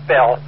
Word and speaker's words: Bill: It is Bill.